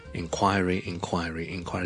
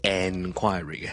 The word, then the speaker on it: inquiry: 大家都是读 inquiry, 重音在于第二个音节 ,quire, so it's inquiry, 前面呢只需要一个很轻的 in, 但如果 N 音呢很多时候受后面的 K 音影响呢就会变成好像 in 咁样嘅 inquiry 就唔需要咧读成 enquiry 嘅，虽然佢嘅串法系 en，咁个原因就系因为佢第一个音节咧佢唔系重音，唔系重音咧，通常咧我 en 咧都唔会读 en 嘅，通常读 in 就可以啦。